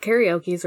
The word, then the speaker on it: karaoke: How do I pronounce it in English, actually? – That's right. Karaoke's right.